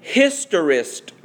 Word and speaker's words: historist: The second view is the historist view, and I will spell it for you. H-I-S-T-O-R-I-S-T. If it sounds like history, that is the root word.